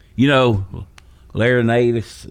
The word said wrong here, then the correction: Larrañaga: is that how you say it? I think so. Man, that guy's a good coach that goes totally Larrañaga. Larrañaga, yeah.